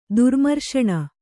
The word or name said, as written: ದುರ್ಮರ್ಷಣ